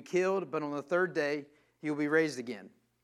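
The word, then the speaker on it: day (δεῖ): The Greek word for must is day, which is, it's spelled D-A-I, but pronounced like day.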